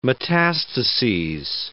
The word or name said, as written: metastases